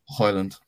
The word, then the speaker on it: Højlund: Højlund.